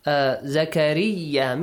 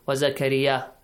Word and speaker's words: زكريا: Zakariya, make sure you pronounce the tashdeed. Sometimes students might forget this. Wa Zakariya, Zakariya, the tashdeed. In recitation, it's important. Wa Zakariya, double ya we have.